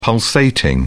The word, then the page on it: pulsating: British English